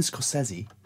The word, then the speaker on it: Scorsese: I don't want to spoil it for you, but Martin Scorsese is directing... That is how you say it.